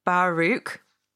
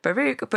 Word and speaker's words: Baruch: The pronunciation is approximately Baruch. So I didn't need to go to town on the CH as much as I did, perhaps. Baruch, but can be pronounced with the same ending as Loch, as in like Scottish, to be more authentic.